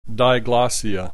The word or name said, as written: diglossia